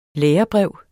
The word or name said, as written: lærebrev